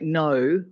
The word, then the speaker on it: know: So if you think of oh, the K's, you know, in something like no, which we don't pronounce the K in no, that K in OW, you know, that went through a stage of being voiced and then dropped off.